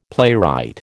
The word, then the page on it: playwright: American English